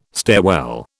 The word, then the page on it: stairwell: British English